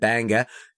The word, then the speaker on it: Bangor: And also which I always pronounce the way that you said it, Banga, is Bangor. – Banga. – Bangor. And Bangor.